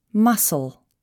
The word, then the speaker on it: muscle: The letter C is not pronounced in the ending S-C-L-E, muscle.